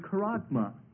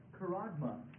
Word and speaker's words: charagma: Now, the word mark is the Greek word karagma. Karagma. Karagma, which is spelled C-H-A-R-A-G-M-A.